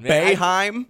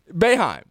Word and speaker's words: Boeheim: Boeheim. – Boeheim. – This thing Bay, like Bay. – Yeah. Yeah. – And then Heim. – Boeheim.